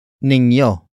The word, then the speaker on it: ninyo: The next word is ninyo.